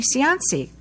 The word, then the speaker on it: Cianci: I'm your host, and with me is our dear friend, Amy Cianci, rhymes with Beyonce.